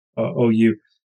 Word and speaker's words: Zhou: I was talking about the Duke of Zhou, and it's spelled Joe. So it's not the Duke of Zhou. It's Zhou. This is something.